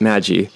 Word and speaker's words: magi: So, the word magi, or some pronounce it magi, comes from the word magoi, which could mean magician.